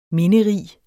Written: minderig

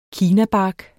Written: kinabark

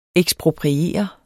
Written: ekspropriere

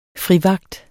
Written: frivagt

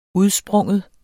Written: udspring

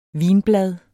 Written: vinblad

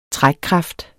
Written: trækkraft

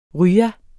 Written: rya

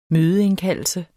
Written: mødeindkaldelse

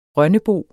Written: rønnebo

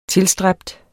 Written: tilstræbt

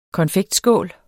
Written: konfektskål

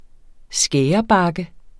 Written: skærebakke

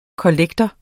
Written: kollektor

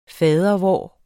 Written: fadervor

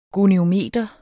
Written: goniometer

